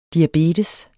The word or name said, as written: diabetes